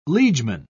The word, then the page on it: liegeman: British English